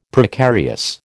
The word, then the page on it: Precarious: American English